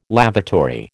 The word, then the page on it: lavatory: American English